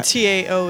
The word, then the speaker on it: TAO: Uh, and T A O is pronounced Tao.